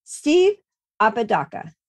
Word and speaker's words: Apodaca: Today we have the awesome opportunity to be joined with our guest, Steve Apodaca. Did I say it correctly? – You said it 100% correct.